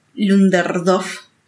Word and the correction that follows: Ludendorff: Ludendorff. Ludendorff. Ludendorff. Ah, no era tan difícil.